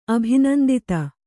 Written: ಅಭಿನಂದಿತ

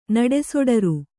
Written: ನಡೆಸೊಡರು

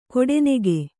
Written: ಕೊಡೆನೆಗೆ

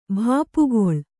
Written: ಭಾಪುಗೊಳ್

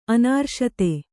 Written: ಅನಾರ್ಷತೆ

